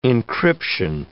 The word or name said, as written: encryption